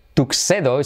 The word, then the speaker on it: tuxedo: Tuxedo se dice tuxedo. Tuxedo, acordaos de un taxi.